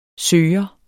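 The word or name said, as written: søger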